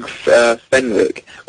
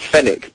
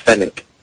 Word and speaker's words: Fenick: Yeah, well, whenever I've told people about my name being Fenwick, well, it's Fennec, but I say Fenwick because people spell it F-E-N-I-C-K if I don't say Fenwick. So I just throw in the W so people are like, yeah, oh, it's Fenwick, F-E-N-W-I-C-K, and then they don't get a spelling wrong, but it's actually pronounced Fennec.